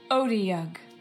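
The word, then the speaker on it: Otyugh: Utiyug.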